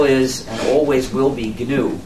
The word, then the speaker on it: GNU: So, when it's the name of our operating system, please pronounce a hard G. Pronounce it GNU.